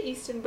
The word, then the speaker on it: Easttown: Oh, that night that we watched Mare of Easttown. Or is it Easton? – I say Easton, but I don't know. But Mare of Easton. – Yeah, say it like that.